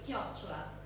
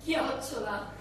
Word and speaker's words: chiocciola: How do you say that in Italian? – Chiocciola. – Can you say that very loudly for everybody so they can say it? – Chiocciola.